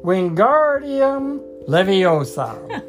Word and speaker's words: Wingardium Leviosa: You're saying it wrong, Harry heard Hermione snap. It's Wingardium Leviosa. Make the gar nice and long.